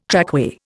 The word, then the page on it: trackway: Indian